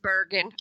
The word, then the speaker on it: Bergen: It's just Bergen. Although if you're from here, it's Bergen accent is a bit more rolling R, but I cannot actually roll my R's.